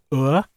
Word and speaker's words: Ur: I mean, on that tune, I believe we might have been listening to a golden lyre of Ur.